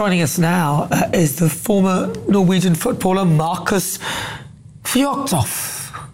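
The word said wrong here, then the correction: Fjørtoft: Fjortoft. Fjortoft. So you were pretty close.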